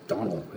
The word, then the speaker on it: Domhnall: Am I saying his first name right? – I think it's Donald. I think it's pronounced Donald. – Donald? Is it? – Yeah, okay. – It's D-O-M-N.